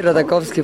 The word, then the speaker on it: Ratajkowski: Ratakowski. Eh, ¿Lo dije bien? Sí, Ratakowski. Ratakowski. Exacto.